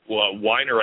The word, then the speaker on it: Weiner: I don't know what, Weiner, I guess, means someone from wine. And they're pronounced Wiener and Weiner in German.